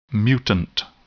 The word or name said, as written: mutant